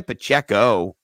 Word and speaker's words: Pacheco: Well, I've got a guy who you could sell low, and that's Isaiah Pacheco rhymes with sell low, you know?